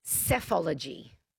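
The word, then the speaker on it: psephology: It's, yeah, and it's pronounced cephology.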